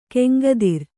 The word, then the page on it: ಗಂಗೋತ್ರಿ: Kannada